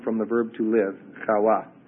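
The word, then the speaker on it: Chawa (חוה): The Hebrew is Chawa, C-H-A-W-A-H. C-H-A-W-A-H. The Hebrew pronunciation would be Chawa.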